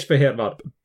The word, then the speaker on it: Fehérvár: You know how to pronounce it. – Yes. As in Székes Fehérvár. – Beautiful. Beautifully done.